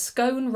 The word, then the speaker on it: scone: Well, Hazel Quinlan says, scone rhymes with bone.